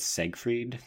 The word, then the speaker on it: Seyfried: Oh, also, can we pronounce her name correctly? It's Siegfried.